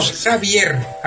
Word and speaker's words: Xavier: Xavier, acabado en R. – En R es Javier, claro, pero como en catalán él tenía puesto el nombre en catalán, pues es Xavier, porque en R no se pronuncia.